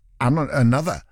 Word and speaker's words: Anotr: A-N-O-T-R. It's not Another. It's Anotr. Anotr. Featuring 54 Ultra. If we're saying it as it's written, Anotr. But then, you know, it's only dance music artists that do that. Go, well, when it's spelt, but it's pronounced Another.